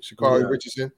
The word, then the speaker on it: Sha'Carri Richardson: Sha'Carri Richardson? – Yeah.